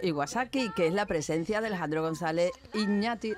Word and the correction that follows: Iñárritu: Iñarritu. Vaya, Iñarritu lo he dicho bien.